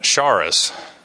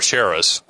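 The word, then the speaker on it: charis (χάρις): The Greek word is pronounced charis. Or charis,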